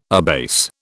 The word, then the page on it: abase: British English